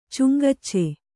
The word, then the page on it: ಚುಂಗಚ್ಚೆ: kannada